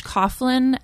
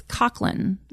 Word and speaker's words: Coughlin: He pronounces it Coughlin, which is incorrect, but to be perfectly honest, Coughlin's not even correct. It's Coughlin, which is Gaelic, so I don't even pronounce it correctly. So either or. – So it's C O U G H L I N. – Yeah, it's Irish, and so it's like Coughlin.